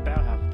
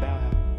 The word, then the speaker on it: Bauhaus: But I love that Bauhaus stuff. I think I'm pronouncing it right. Bauhaus.